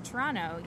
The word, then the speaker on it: Toronto: which apparently is Toronto. You don't say the second T.